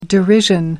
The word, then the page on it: derision: American English